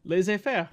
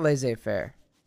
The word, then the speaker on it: laissez-faire: Laissez-faire. – It's not laissez-faire. It's not a Z. Yeah. It's not pronounced that way. It's laissez-faire. Laissez-faire.